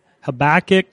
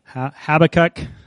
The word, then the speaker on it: Habakkuk: My mom was teaching me the books of the Bible, and she always had trouble with Habakkuk. So she said, just remember, have a cookie, and then you'll be pretty close.